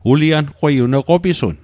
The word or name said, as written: qopisun